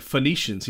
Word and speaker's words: Phoenicians: And he was like the phono. We're like Phoenicians. He's like, oh, okay.